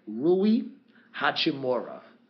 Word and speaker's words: Rui Hachimura: Washington Wizards drafted number 9, Rui Hachimura. I may be mispronouncing that name. I want to say it correctly because he is a fabulous player. It is Rui Hachimura.